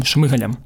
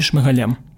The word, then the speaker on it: Шмигалем: Тому завдяки Зеленському у нас тепер новий, трошки старіший з віком склад кабінету міністрів на чолі з Денисом Шмигалем чи Шмигалем здається Шмигалем. Наголоси це важливо.